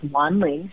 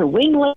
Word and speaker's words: Wan Ling: And people would say things like, to Wingling or Wanglong, mm-hmm. or mm-hmm. one time I even remember Wendy, and it was just like pretty horrifying and shocking that people wouldn't even try to pronounce it even phonetically, like the, the letters they were using were all wrong.